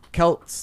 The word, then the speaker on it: Celts: Anyways, so yeah, the Celts, though, is how it's actually pronounced. It's with a hard C.